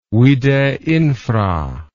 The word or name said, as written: vide infra